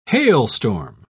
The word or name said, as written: hailstorm